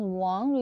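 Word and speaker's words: Wang (王): Inside this character, we pronounce Wang.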